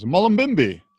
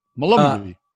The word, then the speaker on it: Mullumbimby: Mullumbimby. – Mullumbimby. – Mullumbimby. – Was mispronounced. – Whatever mispronounced it is. Make sure the fly doesn't go into your mouth when you pronounce it. Mullumbimby.